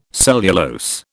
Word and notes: cellulose: British English

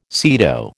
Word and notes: SEATO: American English

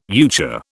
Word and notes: euchre: British English

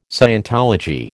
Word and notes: scientology: American English

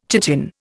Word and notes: chitin: Indian

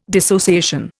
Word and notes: dissociation: Indian

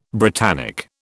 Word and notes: Britannic: British English

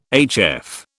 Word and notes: HF: British English

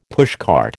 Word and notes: pushcart: American English

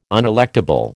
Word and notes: unelectable: American English

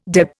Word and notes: deb: Indian

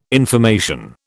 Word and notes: information: British English

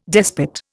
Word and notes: despot: Indian

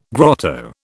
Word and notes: grotto: British English

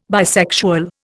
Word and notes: bisexual: Indian

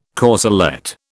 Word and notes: corselette: British English

